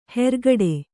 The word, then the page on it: ಹೆರ್ಗಡೆ: hergaḍe